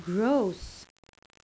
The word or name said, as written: gross